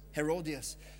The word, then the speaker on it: Herodias: But his wife, I'm not sure how the English pronunciation is, but is it Herodias? Herodias.